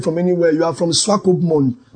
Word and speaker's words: Swakopmund: He said, Swakopmund.